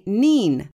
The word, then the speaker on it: Nene: There's a river in England spelled N-E-N-E. It runs through two counties that can't agree how to say it. Northamptonshire calls the 90-mile waterway Nen, while over in Cambridgeshire they say Neen.